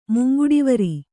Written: ಮುಂಗುಡಿವರಿ

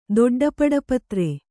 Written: ದೊಡ್ಡಪಡಪತ್ರೆ

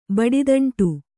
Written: ಬಡಿದಂಟು

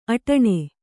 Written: ಅಟ್ಟಣೆ

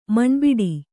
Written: ಮಣ್ಬಿಡಿ

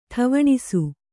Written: ಠವಣಿಸು

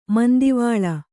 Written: ಮಂದಿವಾಳ

